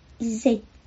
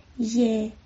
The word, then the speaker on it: 亦: 意的粤语发音是 ze，是一个短音。一 a z，但是我听到演唱者的发音是 ye，主要是在结束的时候没有把气流截住，就是说没有把这个音吃进去，变成了一个开放式的结束。